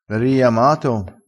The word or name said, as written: riiama